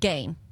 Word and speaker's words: Gein: So it is pronounced Gein. – Yes.